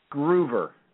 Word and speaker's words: Groover: Groover. – Groover. Okay. That's, I think that's how I pronounce it. So, I was thinking maybe Grover, but, you know, Groover sounded more reasonable.